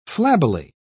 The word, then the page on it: flabbily: British English